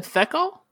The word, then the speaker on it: Thekal: I pronounce it Thekal. – Thekal? Thekal? It's definitely not Thekal, because that kind of comes off like fecal, and no one wants that.